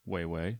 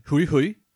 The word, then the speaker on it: HueHue: It's spelled H-U-E-H-U-E. But it's pronounced way way. – Hui hui.